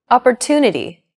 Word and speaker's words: opportunity: Opportunity. The stressed syllable to in opportunity is deemed more important, so it's pronounced longer than all other syllables.